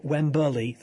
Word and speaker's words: Wembley: And note, I'm very impressed the way you actually use the traditional footballing routine there with respect to Wembley, the three syllables as opposed to Wembley.